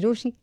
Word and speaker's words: Rochie: मेरा नाम है मिर्ची रोशी, नाम की स्पेलिंग है ROCHIE. सो डू व्हाट एप्स यू हैव टू डू, लेकिन कहीं मत जाना क्योंकि वापस तो यही है आना.